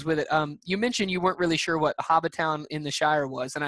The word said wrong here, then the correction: Hobbiton: It's pronounced Hobbiton. It's where the hobbits. It's where Bilbo Baggins lives in The Hobbit, and it's where Frodo is from in The Lord of the Rings.